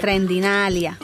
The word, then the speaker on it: Trendinalia: Una sí, pero sin ING, ¿no? Es Trendinalia. – No, en español, Trendinalia. – Ah, como lo pronunciaría en español. That's ya, right. Trendinalia.